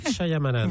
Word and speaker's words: Shyamalan: Mais on vous a pas vu uniquement sur M6, ensuite France 2, avec l'évocation du film Signe de Night Shyamalan, enfin je sais pas si... – Shyamalan.